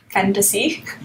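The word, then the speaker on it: Phantaci: P-H-A-N-T-A-C and then small I. – And how do you pronounce it? – Fantasy. – Yes! – Fantasy. His album name. – But with a twist, with a P-H and a C-I.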